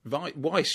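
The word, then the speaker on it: Weisz: I thought it was excellent, especially the performances of Fiennes and Wish, and feel that they should receive... – Is that how you pronounce it? Weiss, surely.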